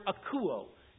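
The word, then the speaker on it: akouo (ἀκούω): It is the word akouo. – You can hear with you, if you were spelling it in English, it would be A-K-O-U. A-K-O-U-W. The W is silent in the Greek.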